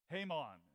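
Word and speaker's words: Heman: Haman is the man's name. It's spelled like He-Man, but it's pronounced more like a Jamaican greeting than a superhero.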